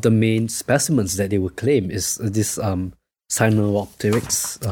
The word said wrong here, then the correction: Sinosauropteryx: How do you pronounce that? – Sinosauropteryx. Yeah. That